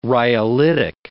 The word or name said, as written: rhyolitic